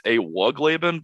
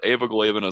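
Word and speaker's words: Ewigleben: I believe that the W is more pronounced. I believe it's Evigleben, but Evigleben is also very close. And Harrison says that he's still not completely sure, and the guy it's named after died, so he can no longer ask him. – Yeah, I think it depends on if it's pronounced like the German, because it's just two German words stuck together. It's Evig and Leben.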